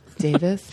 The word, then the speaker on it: Davis: It rhymes with Mavis. – Davis? I went to Davis? – Yeah, University of California, Davis.